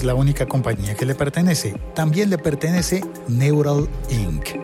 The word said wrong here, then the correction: Neuralink: No, perdón, lo dije mal. Neuralink. En el siglo XXI de hoy ya habíamos hecho un episodio contando la historia de Neuralink.